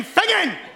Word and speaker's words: Finzean: G-A-R-I-O-C-H. – And the town. – Oh, Lord, give me strength. It's Fingen. F-I-N-Z-E-A-N. – Could you spell it properly, please? – I did. F-I-N-Z-E-A-N. Fingen!